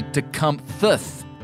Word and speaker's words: Tecumseh: And we all know already that we're all pronouncing it wrong because it was actually Tecumthuth, which is an odd pronunciation for our ear.